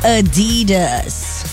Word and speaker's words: Adidas: It's Adidas. The stress on the second syllable, named after Adolf Adi Dassler, the company German founder.